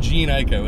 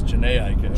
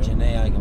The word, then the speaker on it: Jhené Aiko: It's not Jean Ico, it's Jhené Aiko. – Jhené Aiko, well, seriously.